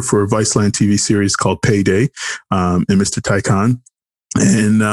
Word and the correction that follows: tachyon: It's a tachyon, but tachyon. It's like, I mean, it's not like a word or anything.